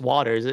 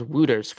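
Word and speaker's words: Wouters: First of all, I'm surprised it's pronounced Waters. I thought it was Wooters for the longest time. And then I was listening to Brendan Batchelor on 650 and he was pronouncing it Chase Waters. So I'm like, okay, that's probably, I assume he's done his research. If he's calling him Chase Waters, but he's, I believe he was an undrafted guy out of Major Junior, right?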